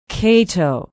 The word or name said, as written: cato